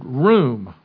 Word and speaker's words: rum: E-X-T-O-L. It comes from the Hebrew word R-U-M. It's pronounced, it would be better pronounced like the word room, R-O-O-M.